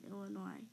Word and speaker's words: Illinois: First things first, the S is silent in Illinois. Nothing annoys an Illinoisan more than when someone pronounces the S in their state's name.